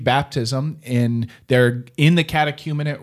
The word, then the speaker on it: catechumenate: The catechumenate, am I saying that right? – You nailed it.